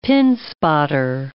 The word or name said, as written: pinspotter